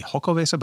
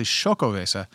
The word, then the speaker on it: Xocoveza: The X in X-O-C-O is pronounced with an S-H sound. – Oh, okay. – I thought it was maybe an H sound, so it would be Xocoveza, but it's actually Xocoveza, as near as I can tell.